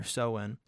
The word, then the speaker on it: Samhain: It's spelled like Samhain. – Okay. – But it's pronounced Samhain or Soin.